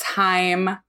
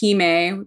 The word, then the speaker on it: Hime: Everybody thinks it's Haim. It's Hime, because I am a big weeb, and Hime is the Japanese honorific for princess.